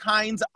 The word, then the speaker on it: Hinds: It's at Patrick Hines _, and my last name is spelled H-I-N-D-S _ on Instagram.